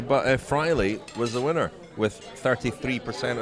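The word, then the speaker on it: Fraile: Lewis Angel Matte of Cofidis, Omar Freil, is that how you pronounce his name? – Freile. – Omar Freile, the Dimension data rider, who's been in a lot of breaks this week.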